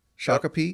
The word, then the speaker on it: Shakopee: And then again, continuing with your Minnesota guys, I'm not sure how you pronounce his hometown, Shakopee, Will Cordes? – Yeah, Shakopee.